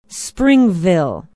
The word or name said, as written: springville